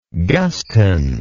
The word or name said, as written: Guston